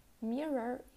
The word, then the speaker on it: mirror: Mirror, you pronounce it like a long R instead of two short R's. You don't say mirror, there's clearly no O sound, you say mirror.